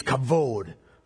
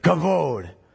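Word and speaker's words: kavod (כבוד): It's the word kavod. K-A-B-O-D. But you kind of pronounce it like Spanish words with the B. It's kavod. Okay? Say it with me. Kavod.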